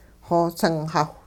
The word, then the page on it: xosingxawh: hupa